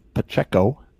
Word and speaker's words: Pacheco: But you have his bestie in the backfield, Pacheco.